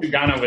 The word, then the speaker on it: Sugano: So the name that's really intrigued me in your piece, and I'm sure I'll butcher it, but Tomoyuki Sugano, one or the other, I hope. – Sugano is correct. Yeah, you got Sugano.